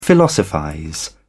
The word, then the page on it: philosophize: British English